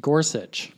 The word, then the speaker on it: Gorsuch: Is it Gorsuch or Gorsuch? – Gorsuch. – Oh, okay. I've been saying it wrong the whole time.